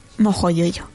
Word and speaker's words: Mojo Jojo: Mojo yo yo, porque cambian, porque cambian la pronunciación de la J en la segunda. Y dicen Porque sí. – sean consistentes, mojo jojo.